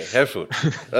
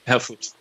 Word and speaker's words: Herfurth: Herfurth. – Herfurth. – Herfurth, yeah, just forget about the last H and you're fine. – Okay, Herfurth. Okay. – Herfurth. – All perfect. Right.